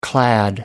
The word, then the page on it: clad: American English